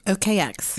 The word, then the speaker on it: OKX: OKX? – OKX.